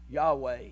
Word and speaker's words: Yahweh: It's YHWH. Yahweh is the way that they would pronounce it.